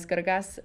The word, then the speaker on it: Grgas: Bog Yasa Miss Grgas. That's how you actually pronounce my last name. Is you roll the R a little bit.